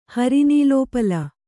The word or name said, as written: ಹರಿನೀಲೋಪಲ